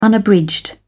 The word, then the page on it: unabridged: British English